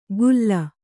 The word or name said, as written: ಖುಲ್ಲ